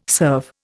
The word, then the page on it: serve: Indian